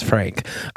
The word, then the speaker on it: Frank: I feel like we can go back to the original and true pronunciation of my name, which is Frank.